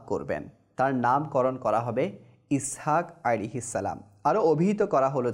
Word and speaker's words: ইসহাক: ইসহাক শব্দটির মূল উচ্চারণ হলো ইয়াসহাক, এটি ইব্রানি শব্দ যার আরবি শব্দের অর্থ হলো সে হাসছে বা সে হাসতেছে।